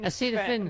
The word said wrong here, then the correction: acetaminophen: Acetaminophen. – Ooh, that's a hard one. How do you say it? Acetaminophen, sir. Acetaminophen.